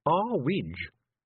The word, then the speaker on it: Earwig: It's too high, Mrs. Earwig, said Tiffany. – It's pronounced Arwidge, said Mrs. Earwig coldly.